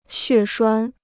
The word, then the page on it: 血栓: xuè shuān